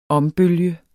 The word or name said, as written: ombølge